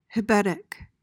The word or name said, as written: hebetic